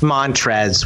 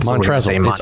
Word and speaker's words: Montrezl: Because and we know it's pronounced Montrez. We just love the way it's spelled. – Montrezl.